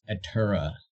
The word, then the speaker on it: Ateura: But the first band name that we ever had was A-T-E-U-R-A. So that would be pronounced Atura.